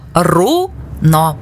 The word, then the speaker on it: руно: Як правильно руно чи руно? Правильно руно. Наголос на у.